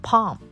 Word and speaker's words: palm: 这个 palm, P-A-M。这个 palm 是手心，这个 l 没有发音。